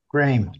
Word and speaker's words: Graeme: So it's pronounced Graeme. It's like frame with a G. – Graeme.